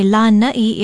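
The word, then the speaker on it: Lāna'i: Lana'i is spelled L-A-N-A-Okina'i, and there is a makron or kahako, a stress mark over the first vowel. Say Lana'i if you're talking about the island.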